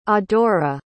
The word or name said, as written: adora